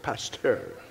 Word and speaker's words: Pasteur: Some of you may remember in school hearing about Louis Pasteur. I don't know if that's how you say it. Any French people here? Pasteur. Is that? Okay. There you go.